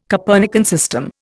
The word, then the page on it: Copernican system: Indian